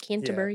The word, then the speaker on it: Canterbury: Justin Welby, the Archbishop of Canterbury. Did I say that right? – Canterbury.